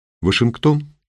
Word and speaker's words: Вашингтон: «Вашингтон?» «Вашингтон. С ударением на первый слог.